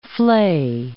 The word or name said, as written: flay